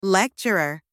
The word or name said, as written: lecturer